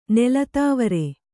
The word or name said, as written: ನೆಲತಾವರೆ